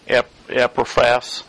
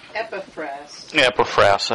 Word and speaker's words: Epaphras: How do you pronounce that name? Epaphras. – Epaphras. – I actually got a pronunciation right.